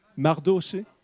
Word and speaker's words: Mardoché: Donc elle a demandé, nous lisons dans Esther, « Va, rassemble, envoya dire à Marchoché, qui est, je crois, son cousin, je ne sais pas si je prononce bien, Mardoché, voilà, vous prononcez mieux que moi.